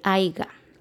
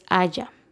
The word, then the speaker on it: haya: El AIGA no existe tampoco. No, es ni parte del verbo, no es parte de ninguna conjugación. Lo correcto es haya. Ah, vamos a poner que digan, espero que haya ido a la cita. No, espero que haya ido a la cita. Y se escribe H-A-Y-A.